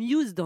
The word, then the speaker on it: Youzd: Eh bien, il existe une solution qui s'appelle Used, Y-O-U-Z-D. – Oui, ça se prononce comme Used en anglais.